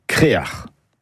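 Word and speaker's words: creac'h: Le mot breton, c'est créar. Et ce mot, on le retrouve dans des hameaux ou des quartiers, même pour un phare. Et la bonne production est crer ou créar. Créar, c'est une prononciation plus du Nord Finistère.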